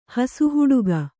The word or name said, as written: ಹಸುಹುಡುಗ